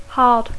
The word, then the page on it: hard: English